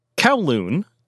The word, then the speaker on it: Kowloon: So Kowloon is an anglicized pronunciation of the Cantonese phrase Kowloong, which literally means nine dragons.